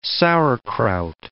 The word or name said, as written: sauerkraut